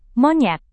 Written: monyet

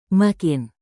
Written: makin

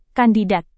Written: kandidat

